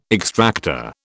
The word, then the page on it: extractor: British English